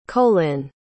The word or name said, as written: colyn